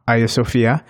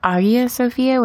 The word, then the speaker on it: Hagia Sophia: It's like Hagia Sophia without the H. Or maybe Hagia? Some people say Hagia Sophia, but I think it's a little bit more of a silent H.